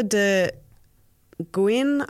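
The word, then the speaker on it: Le Guin: S-E-A? Earth-ocean cycle? – Yeah. So Ursula de Guin, I think is how you pronounce her name.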